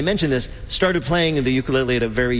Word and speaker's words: ukulele: the ukulele.